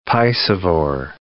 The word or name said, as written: piscivore